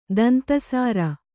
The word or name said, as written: ದಂತಸಾರ